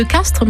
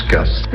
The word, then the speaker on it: Castries: Quand on parle de village de Castries en Languedoc, dans la vieille langue du pays, on prononce Castres. Et comme au XVIIe siècle, cette langue était beaucoup plus parlée ici que le français, on a conservé l'usage de la prononciation ancienne.